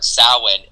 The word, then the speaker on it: Samhain: And then when I heard Samhain, I was like, what? – Yeah. – Like, not even close to how I had pronounced right.